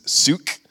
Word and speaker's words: Suk: This is Suk. It's pronounced like Luke, but with an S.